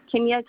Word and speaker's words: Kenya: Kenya, K-E-N-Y-A. – I'm sorry, could you spell it again? – K-E-N-Y-A.